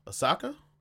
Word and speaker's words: Ahsoka: How do I say this girl's name? Ahsoka? – Yes.